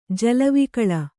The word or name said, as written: ಜಲವಿಕಳ